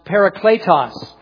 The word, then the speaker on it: parakletos: Remember, we've taught that that's the Greek word there is pronounced parakletos.